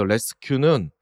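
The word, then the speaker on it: Resque: 여기서 레스큐는 뭐 구조하다의 그 발음을 그냥 도용한 거지 갖다 쓴 거지만 레스 이제 뒤에는 QU이죠.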